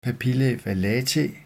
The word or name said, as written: papillae vallatae